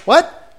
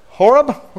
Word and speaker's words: Horeb: How do you say it? Is it Hob? Hoab? Moab? What? What? – Horeb. – Horeb? Okay, Mount Horeb.